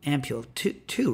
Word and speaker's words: ampule: How many syllables is it? – Ampule, two, right? Ampule, or it's two, right? – It's two, but with a high letter to syllable ratio.